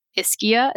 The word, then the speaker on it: Ischia: And so Dom had just filmed at this island, Ischia.